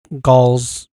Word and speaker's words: Gal: Her first name is pronounced like Doll, and her last name rhymes with afloat. So, oh, it's Gal Gadot.